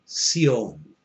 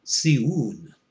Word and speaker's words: Zion: And that particular word, Zion, which would be pronounced Sion, Sion, and also pronounced Siun, a monumental or guiding pillar, also has a particular meaning in the symbolic letters.